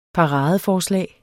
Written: paradeforslag

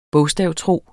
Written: bogstavtro

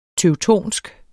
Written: teutonsk

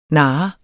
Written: nar

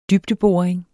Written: dybdeboring

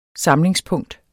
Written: samlingspunkt

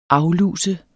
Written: afluse

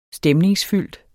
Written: stemningsfyldt

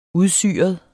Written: udsyret

